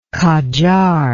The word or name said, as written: Kajar